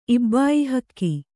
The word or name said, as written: ಇಬ್ಬಾಯಿಹಕ್ಕಿ